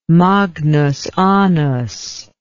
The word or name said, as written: magnus annus